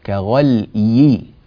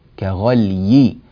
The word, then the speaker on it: كغلي: كذلك ننبه إلى في الآية 46 إلى قوله تعالى كغلي الحميم، كغلي كغلي، الياء هنا مكسورة وتحتاج إلى عناية وبذل للمجهود في نطقها نطقا صحيحا، ويزداد الأمر صعوبة عند الوقف، فإذا وقفنا مثلا على هذه الكلمة لأي سبب نقول كغلي كغلي كغلي، ولاحظوا أنني أتيت بالياء ووضحتها وحافظت على سكون اللام فلم أكسرها، لم أقل كغلي كغلي غلي وكسرت، لا خطأ، كغلي كغلي كغلي هكذا.